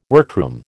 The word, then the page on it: workroom: American English